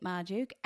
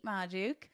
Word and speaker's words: Magdziuk: And you can find me, Kate Magdziuk, at Kate Magdziuk. – Yes. Should you spell your last name? Can anyone spell it? – Magdziuk.